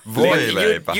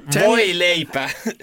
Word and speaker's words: voileipä: Voileipä. – You didn't pronounce that so good. Voileipä.